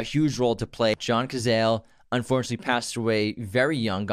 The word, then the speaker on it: Cazale: And then John Cazale, who, John Cazale, Cazale.